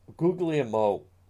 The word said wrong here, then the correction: Guglielmo: hold on. Why do I keep mispronouncing his name? And I say that because I don't like when people mispronounce. Guglielmo, that's it.